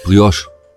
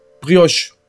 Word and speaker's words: Brioche: Ah, cool wie spricht man das aus? Bräuche, Broiche, diese Brot, diese weiche. – Brioche. – Brioche. Brioche. Aber das ist doch französisch, ne? – Das ist französisch, irgendwie.